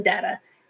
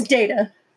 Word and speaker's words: Data: She called him Data, and he didn't like it. He's like, my name is Data. Please pronounce it correctly.